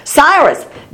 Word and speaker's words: Cyrus: Cyrus.